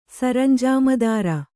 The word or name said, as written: ಸರಂಜಾಮದಾರ